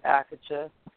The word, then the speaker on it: Akatha: A-K-A-T-H-A. T-H is not pronounced as the tha in English, in the English versions. It's in higher Sanskrit, which is the word. It's a hard T, so it's pronounced Akatha.